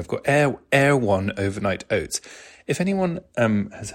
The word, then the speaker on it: Erewhon: And it's spelled E-R-E-W-H-O-N, Air One.